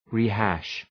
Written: rehash

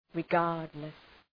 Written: regardless

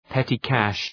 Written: petty cash

